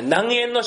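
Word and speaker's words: 南淵: 唐という国に留学していた南苑さん、南の淵って書きます。南苑さんという方にいろいろ政治の要点を教わるんですね。で、それをまとめたものをごめんなさい、南苑の書と言うんです。南の淵の書と書いて南苑の書と読みます。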